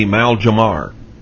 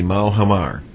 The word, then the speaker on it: Maljamar: The president, the current president, Barack Obama, went to, I think it's pronounced Malhamar, and that is in New Mexico. Now, it may be Maljamar. But if it's got the Spanish pronunciation, it will be Malhamar.